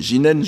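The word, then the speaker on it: jinen (自然): Donc, prononcer au sens, au Japon, jinen dans un contexte bouddhique, parce que le japonais fait très clairement la différence entre le shizen nature actuelle et le shizen bouddhique, qui est alors prononcé jinen, je n'insiste pas là-dessus.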